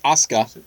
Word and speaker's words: Asuka: Then we had a lot of previews for Asuka, which we now realize is how you say it. Because I think we had both been saying Asuka. Because yeah, that's how it's spelled. – We're America. – Right, we're so America. – Americanized your name. – It's spelled Asuka. We say Asuka. – If you put an A at the end, I pronounce the A. – Right, but it's apparently just Asuka.